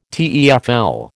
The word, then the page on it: TEFL: American English